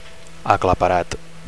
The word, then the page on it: aclaparat: Catalan